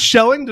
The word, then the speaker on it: Schelling: My man, my friend, Chris Schelling.